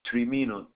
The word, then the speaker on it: Trimino: Trimino, T-R-I-M-I-N-O.